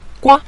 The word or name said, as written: gua5